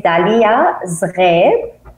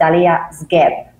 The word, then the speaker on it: Dalia Zghreb: Yes, it is Dalia Zghreb, but because we don't have the R in the English language, so you can pronounce it Dalia Zghreb.